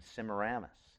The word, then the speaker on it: Semiramis: Semiramis is a difficult word. It's spelled S-E-M-I-R-A-M-I-S. So it's kind of Semiramis is kind of how it's spelled. You